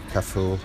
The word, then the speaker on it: Cafu: Cafu? – Yeah, Sami Cafu.